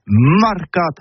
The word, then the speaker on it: mercat: Eh bien, disais à tous, mais le mercat de l'Uni ou le mercat des rebelles, mais en Gascogne toulousaine, c'est prononcé les marcat avec un A.